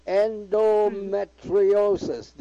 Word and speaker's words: endometriosis: Endometriosis. Did I do that all right? – You did. Endometriosis. You got it.